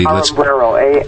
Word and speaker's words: Armburo: And again, we keep seeing these and hearing about these sad cases like this, but the case of Lily Ambruro, and I hope I'm pronouncing that properly. – Ambruro, A-R-M-B-U-R-O, and she was a 24-year-old mother of an infant at the time.